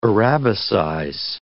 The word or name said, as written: arabicize